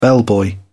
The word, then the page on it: bellboy: British English